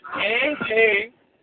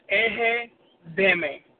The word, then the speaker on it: Egedeme: It's Ehe-Deme. Kind of rhymes. It's Ehe-Deme. – Mm-hmm. – E-G-E-D-E-M-E.